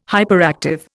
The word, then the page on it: hyperactive: Indian